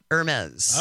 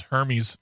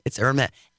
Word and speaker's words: Hermès: Because how do you pronounce it? – Hermès. – All right. – It's very snooty. – I thought it was Hermes. – No, it's Hermès.